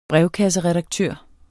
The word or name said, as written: brevkasseredaktør